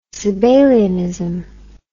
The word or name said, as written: Sabellianism